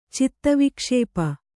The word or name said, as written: ಚಿತ್ತವಿಕ್ಷೇಪ